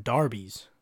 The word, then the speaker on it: Derby: But man, I was watching this thing, it's called like, for me, it would be Derby Days, cause it's spelled D-E-R-B-Y, Derby, but this dude is British and he calls them Darbies, like there's an A in it.